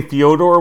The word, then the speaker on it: Fyodor: Not only did you say Fyodor, which is probably the real pronunciation, but you're right. Fedor would win.